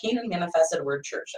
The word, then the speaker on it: Canaan Manifested Word Church: You are the bishop of the Canaan Manifested Word Church.